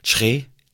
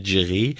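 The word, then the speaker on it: Kyereh: Er hat ja jetzt gesagt, wie er richtig ausgesprochen wird, nicht mehr Tschre. Das hat er jetzt im Interview, im Startinterview mit Freiburg hat er gesagt, sein Name wird Tschre ausgesprochen und nicht Tschre.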